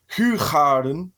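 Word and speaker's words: Hoegaarden: I've heard it's pronounced Huchaden.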